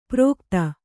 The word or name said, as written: ಪ್ರೋಕ್ತ